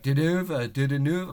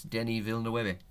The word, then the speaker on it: Denis Villeneuve: And that was, he tried it like two times, and he's like, Didanouve. I'm like, no, it's Denis Villeneuve, or Villeneuve. He butchered it.